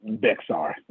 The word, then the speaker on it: Bexar: Right, Bayer County. Is that how you say it? – Bexar.